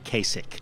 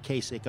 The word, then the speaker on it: Kasich: After Elsa, I'm trying to figure out what rhymes with Kasich. – LASIK eye surgery, maybe?